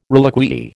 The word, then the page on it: reliquiae: American English